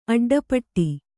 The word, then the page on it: ಅಡ್ಡಪಟ್ಟಿ: aḍḍapaṭṭi